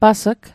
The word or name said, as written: pas'g